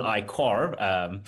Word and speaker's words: iCaur: is the iCar or the iQuar. You're never quite sure how to pronounce it because I think it's spelled I-C-A-U-R.